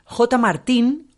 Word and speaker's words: JXTA: Martín, ojo que se escribe J XTA. No se escribe J y ya está. Se, escribe JXTA. El juego de marketing me parece muy acertado. Pero le he preguntado a su representante, ¿pero cómo lo pronuncio? Ah, ¿Cómo, pronuncio yo JXTA? O, dice, no, no, pues J, Martín.